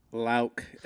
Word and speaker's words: Lauck: Lauck, L-A-U-C-K, Lauck.